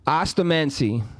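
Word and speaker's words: osteomancy: I Googled the correct pronunciation of this word. – Sound it out. And, clap for syllables. Let's hear it. – Ostomancy.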